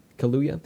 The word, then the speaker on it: Kaluuya: Kaluuya.